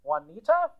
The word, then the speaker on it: Juanita: Well, her name is Juanita, and she is, you know, an otherwise lovely woman. – Does your daughter uh, say her name correctly. – Juanita? – Yes.